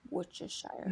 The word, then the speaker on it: Worcestershire: Worcestershire. – I know. I can't say penitentiary, but I can say Worcestershire sauce.